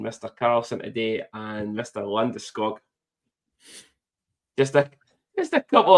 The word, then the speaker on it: Landeskog: And then after that was Gabriel Landeskog. – Right. – I'm saying it correctly now because obviously all their wee videos that have been going online, which is fucking hilarious.